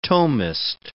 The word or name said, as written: Thomist